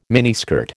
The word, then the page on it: miniskirt: American English